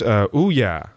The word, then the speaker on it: Ouya: And I believe it's pronounced Ouya. – Ouya. – Exactly right.